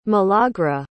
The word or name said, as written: milagra